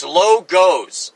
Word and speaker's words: Logos: It's Logos, okay? The proper pronunciation is Logos. There is no ah sound except in the A. The alpha makes ahs in father, okay? The omicron makes a O. Omega makes a long O sound. Okay, omicron goes O, Logos.